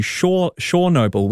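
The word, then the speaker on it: Chornobyl: the media reporting have actually changed the name of Chernobyl to Shornobyl, which apparently is the Ukrainian pronunciation.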